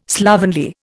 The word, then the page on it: slovenly: Indian